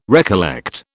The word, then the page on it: recollect: British English